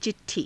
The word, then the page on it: ci_t_thii: hindi